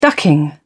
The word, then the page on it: ducking: British English